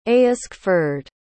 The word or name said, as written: aescford